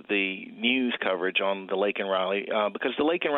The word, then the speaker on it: Laken Riley: Is it Laken? – Mm-hmm. – Laken Riley. – Yes.